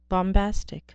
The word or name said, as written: bombastic